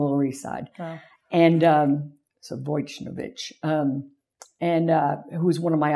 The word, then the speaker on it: Wojnarowicz: I'm going to butcher his name, David Wojnarowicz, or Wojnarowicz, as I always pronounce it.